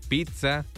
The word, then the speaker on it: pizza: Resulta ser que todas las palabras que tengan doble Z, licenciado, se dice con ese TS. O sea, que suene como un TS, como pizza, como jacuzzi, en vez de jacuzzi, que todo mundo le decimos jacuzzi, licenciado.